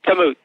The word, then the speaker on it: Kamut: Am I saying that correctly? Kamut? – Yes, Kamut. That's right.